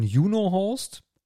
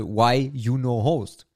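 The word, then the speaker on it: YunoHost: Und das Ganze nennt sich auf Deutsch ausgesprochen Juno Host oder auf Englisch dann, wie man es richtig aussprechen sollte, Why You No Host.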